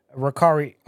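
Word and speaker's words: Raikiri: Raikari, R-A-I, uh-huh. – K-I-R-I, Pro.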